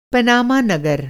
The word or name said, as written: पनामा नगर